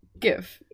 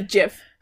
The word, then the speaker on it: GIF: All right, how do you pronounce GIF? – GIF. – It's a GIF. It's not a GIF.